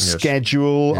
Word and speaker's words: schedule: Schedule, arguably, if you speak American English.